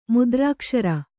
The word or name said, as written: ಮುದ್ರಾಕ್ಷರ